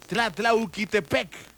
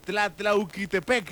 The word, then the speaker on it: Tlatlauquitepec: Por favor, desde Tlatlauquitepec. Ya, sí lo dije bien. Tlatlauquitepec.